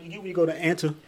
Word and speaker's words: Anta: Something called Anta shoes? – Oh, there you go. Anta, my bad. There you go. – How you spell it? – A-N-T-A.